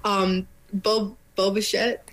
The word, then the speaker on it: Bo Bichette: I always want to say this wrong. Bo Bichette? – Yeah. – What's the Star Wars character? – Boba Fett.